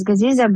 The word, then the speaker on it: Gaziza: So do you say Gaziska? I've been saying Gaziza. Am I saying it wrong? – No, my name is Gaziza, but my friends call me Gaziska.